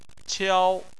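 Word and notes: qiao: tone 1

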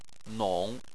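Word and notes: nong: tone 3

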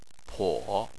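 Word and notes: po: tone 3

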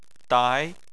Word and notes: dai: 2

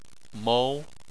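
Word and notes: mou2: tone 2

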